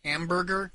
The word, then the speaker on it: Hamburg: Hamburger? – That's what it is, right? – No. – What is it? – On extension. Hamburg. – Why is it spelled like hamburger? – It's Hamburg.